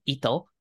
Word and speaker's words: Ito: So I think one of my favorite ones is definitely the game Ito, which is a party co-op game.